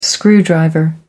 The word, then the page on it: screwdriver: American English